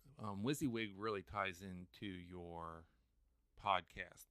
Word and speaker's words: WYSIWYG: so uh, this term, it looks like when you would read it in a document, W Y S I W Y G, pronounced WYSGIG. – Yeah, WYSIWYG. What you see is what you get.